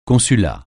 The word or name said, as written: consulat